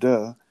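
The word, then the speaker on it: de (德): which has both the te is more with a D sound, de, and it's kind of an intonation up, I hear. – Down. Dao, and then de, up.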